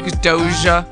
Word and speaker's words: Doja: Doja as in like D-O-J-A, like Doja. – That is how it's spelled, and I don't know if I'm pronouncing it correctly. – Yeah, you are. – Is it Doha? – Doja. – I mean, because at first I said, who is this girl, S-Z-A, and Bobby had to tell me it was SZA.